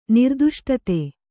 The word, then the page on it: ನಿರ್ದಿಷ್ಟತೆ: nirdiṣṭate